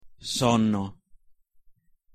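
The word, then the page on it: sonno: Italian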